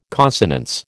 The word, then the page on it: consonance: American English